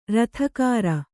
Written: ರತಿಗಾರ

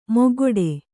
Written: ಮೊಗಗೊಡು

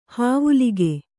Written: ಹಾವುಲಿಗೆ

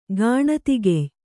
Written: ಗಾಣತಿಗೆಯ್

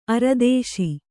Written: ಅರದೇಸಿ

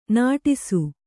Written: ನಟಿಸು

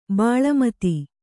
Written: ಬೆಲ್ಮತ್ತಿ

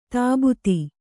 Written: ತಬ್ಬೇತ

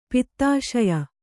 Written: ಪಿತ್ತಾಶಯ

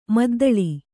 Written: ಮದ್ದಲೆ

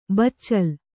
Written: ಬಚ್ಚಲ್

